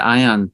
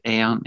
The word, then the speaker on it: aeon: So how's the Ion though? Is that how you say it? Aeon? – Yeah, Ion, Aeon. I don't, yeah. I think it's probably interchangeable.